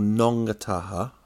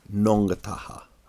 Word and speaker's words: Ngongotaha: In the area where we were, where I lived, in a place called Nongataha, right, which is spelt N-G-O-N-G-O-T-A-H-A, Nongataha, right? The last bit is always like Nongataha, right?